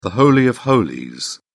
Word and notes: holy of holies: British English

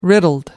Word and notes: riddled: American English

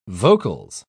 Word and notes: vocals: British English

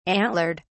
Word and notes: antlered: British English

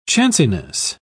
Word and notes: chanciness: British English

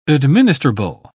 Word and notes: administrable: British English

